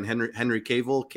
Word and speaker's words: Henry Cavill: Henry Cavill.